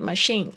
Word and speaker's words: machine: machine 它的那个发音 machine 这个 c h i 是发 sh sh machine。 OK。 Where's the ticket window or where's the ticket machine?